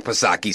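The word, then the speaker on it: Psaki: Oh, well, then I stand corrected, Jen back Pasaki, silent P, of course, because then it's okay, right?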